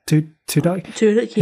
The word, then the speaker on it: Tudyk: Alan Tudyk, is it? Tudyk, yeah.